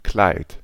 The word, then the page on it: Kleid: [klaɪ̯t]